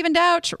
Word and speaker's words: Douch: And Stephen Douch rhymes with couch without the E.